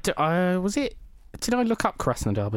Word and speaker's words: Krasnodar: Krasnodar? – Krasnodar. – Krasnodar.